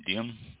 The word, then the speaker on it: Diam: DM. D-I-A-M. DM. I think it's pronounced DM.